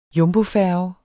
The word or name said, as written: jumbofærge